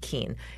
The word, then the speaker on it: Kuehn: So it's pronounced Keene. Mm-hmm. So Brianna Keene, KUEHN.